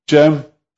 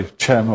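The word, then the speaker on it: Cem: Jem, which you say Chem or Kem or whatever, the C there is pronounced J in Turkish.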